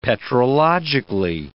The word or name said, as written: petrologically